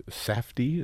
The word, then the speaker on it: Safdie: is directed by Josh and Benny Safdie.